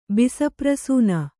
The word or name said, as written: ಬಿಸಪ್ರಸೂನ